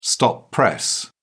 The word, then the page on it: stop press: British English